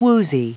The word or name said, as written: woozy